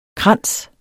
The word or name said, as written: kranse